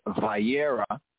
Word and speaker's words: Vayera: we have Wayera, some enunciated and pronounce it as Vayera, but in the ancient Afro-Shemitic pointing, Wayera,